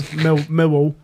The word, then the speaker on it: Millwall: Don't pronounce any of the L's. Millwall.